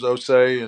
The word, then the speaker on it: Osei: You everybody knows my name's Osei, and you know from the beginning, a lot of people couldn't say my name correctly. You'd be Osei, you know, things like that, and I just kind of let it roll.